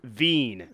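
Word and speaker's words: Wein: In German, wine is pronounced Wien, yeah. – rhymes with seen.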